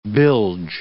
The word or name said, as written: bilge